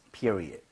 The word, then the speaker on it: period: period는 앞에 이에다 강세를 좀 많이 주시면 좋아요.